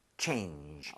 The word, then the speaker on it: change: 이렇게 가면은 여러분 change 이렇게 나오는 것이 아니라 change, 오. – change. 마지막에 입모양은 모으고 발음을 하는데 내려고 이렇게 하려고 노력은 해야 돼요.